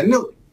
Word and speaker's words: Newt: ¿Quién es Newt? Así se pronuncia, Newt Gingrich.